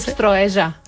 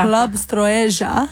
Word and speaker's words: Строежа: How do I pronounce the club? – Club Stroeja. – Stroeja. – Stroeja. – Club Stroeja.